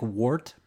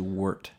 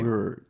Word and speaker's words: wort: It looks like wart, but it's actually wort, how you say wort. – Wort. – With an E? – Yeah, kind of.